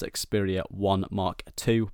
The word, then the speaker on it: Xperia 1 II: Apparently it's pronounced Xperia 1 Mark II, but it's just very confusing and just doesn't really create much clarity in terms of the naming.